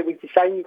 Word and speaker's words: WikiSign: Alors le thème du jour c'est WikiSign, je ne sais pas comment on prononce, donc je te laisse nous présenter ça. – Alors, je vais prononcer WikiSign parce que ça s'orthographie en anglais, W-I-K-I-S-I-G-N. WikiSign est un dictionnaire collaboratif libre de langue des signes lancé en 2006.